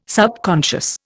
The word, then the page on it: subconscious: Indian